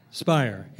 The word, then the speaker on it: Speyer: Is it Speier or Speier? Speier, yeah. S-P-E-Y-E-R? Yeah.